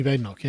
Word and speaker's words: Badenoch: Kemi Badenoch.